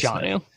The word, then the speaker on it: Jonnu: Yeah, it's John just Jonnu. It literally is just John with a U at the end of it. – Okay. Jonnu Smith would be the other guy that I would be interested in.